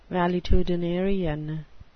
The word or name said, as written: valetudinarian